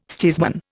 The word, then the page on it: steersman: Indian